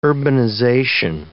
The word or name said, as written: urbanization